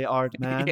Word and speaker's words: Aardman: Hate to correct you, but yeah. It's actually Aardman.